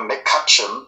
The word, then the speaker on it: McCutcheon: Ich glaube, man hat einfach Platz gemacht für McCutcheon, so spricht man es nämlich aus.